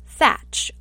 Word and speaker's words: Thatch: So sometimes Blackbeard's name is written and pronounced Teach, like T-E-A-C-H, and other times it's written and pronounced Thatch, like T-H-A-T-C-H, like a thatch roof. I'm going to go against some of the leading experts here who pronounce it Thatch and say, I'm going to say Teach, and I'll tell you why.